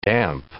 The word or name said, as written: damp